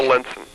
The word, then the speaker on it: Lenson: Bill Lenson. I'm the president of the board. – How do you spell that? – L-E-N-S-O-N.